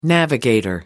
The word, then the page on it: navigator: American English